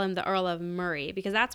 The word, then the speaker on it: Moray: I think it's spelled Moray, but I think it's pronounced Murray.